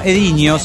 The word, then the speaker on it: Edinho: Comuníquense con Edino. Bueno, Ediño se pronunciaría. Es E-D-I-N-H-O.